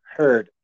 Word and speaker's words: Heard: I am Big Sam Herd, H-E-A-R-D. Even if the name is spelled Herd, H-E-A-R-D, or Herd, H-E-R-D, like, some people, they're like, oh, why is your name that?